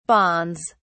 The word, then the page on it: bonds: British English